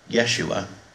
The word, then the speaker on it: Yeshua: It's Yeshua, and we would spell that as Y-E-S-H-U-A, which in ancient Hebrew meant God saves, as Emmanuel means God with us.